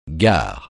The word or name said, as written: gare